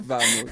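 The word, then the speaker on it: Vamos: Est-ce que c'est Bamos ou Bamos? – C'est Bamos, Bamos. Le V se prononce B.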